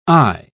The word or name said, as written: ay